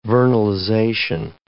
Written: vernalization

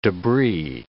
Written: debris

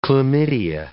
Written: chlamydia

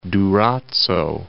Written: Durazzo

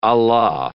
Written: a la

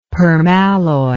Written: permalloy

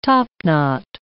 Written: topknot

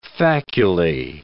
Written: faculae

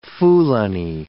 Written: Fulani

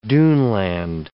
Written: duneland

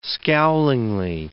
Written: scowlingly

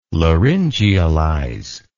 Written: laryngealize

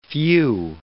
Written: phew

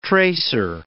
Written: tracer

